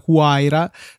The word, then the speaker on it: Huayra: Huayra per questioni di peso.